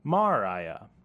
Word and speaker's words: Moriah: The land of Moriah is also really interesting. Discounting the Nikud vowel pointings, since those came later, another acceptable pronunciation here could be Moriah.